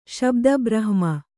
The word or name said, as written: ಶಬ್ದಬ್ರಹ್ಮ